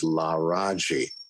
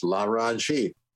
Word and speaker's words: Laraji: The name was Laraji, and they explained that it involved the Egyptian name for the sun god, Ra. – Okay. – And it was three syllables, Laraji.